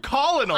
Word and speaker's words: colonel: It's spelled colonel. – I'm with you. – And it's pronounced colonel.